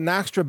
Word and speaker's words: Naxtra: And Naxtra is spelled N-A-X-T-R-A, Naxtra.